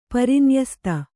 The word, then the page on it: ಪರಿನ್ಯಸ್ತ: kannada